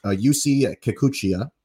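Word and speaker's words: Yusei Kikuchi: I'm going to butcher the heck out of this, but UC Kikuchi.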